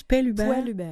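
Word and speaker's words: Poellhuber: c'est P-O-E. – Je le connais bien. – Tu le connais? – Oui. Comment on prononce? – Poil-Hubert.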